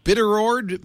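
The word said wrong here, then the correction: Biddeford: Maine. – Bitterford. – Oh, we left out a f. Bitterford. – Yeah, that's basically it.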